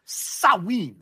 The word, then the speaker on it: Samhain: Samhain.